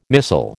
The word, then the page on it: missile: American English